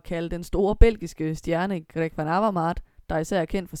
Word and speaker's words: Van Avermaet: hvor øh, Greg så selv fik lov at udtale sit navn rigtigt. – Greg Van Avermaet. – Og her hvor vi jo konstaterer, at vi i Danmark udtaler navnet forkert, øh, vi vil bestræbe os på at gøre det bedre fremover, og rigtig få, få gang i vores øh, lyde nede i halsen.